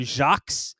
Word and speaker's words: Jacques: but he loses a point because he doesn't pronounce his last name properly. His last name is French. It should be Joe Jacques. As someone who has a French last name and pronounces it properly, I find that offensive.